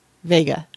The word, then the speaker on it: Vega: Is it Vega or Vega? I never remember. – They said it was Vega. – Vega. Because I was pronouncing it like that, and I said... – And then I probably told you wrong. – Yeah. – And I was wrong.